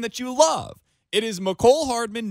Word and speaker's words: Mecole Hardman: It is Mecole Hardman. It is not Mecole Hardman, which is what some had been saying previously. It is Mecole Hardman. Mecole Hardman. That is how you pronounce his name.